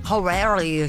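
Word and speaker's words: Jauregui: Horaghi. Who é? – Horaghi. – Horaghi. – Horaghi. Ah, então a gente estava falando errado, cara.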